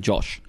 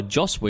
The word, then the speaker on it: Joss: So many people call him Josh. – Yeah. – And it's not. It's Joss. J-O-S-S.